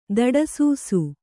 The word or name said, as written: ದಡಸೂಸು